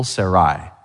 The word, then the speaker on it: Sarai: And they would emphasize the second syllable, Sarai.